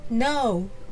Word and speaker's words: know: 知っているという意味の単語は何でしょうか ?No ですね。 No は K, N, O, W と書きます。なぜ K を読まずに No と発音する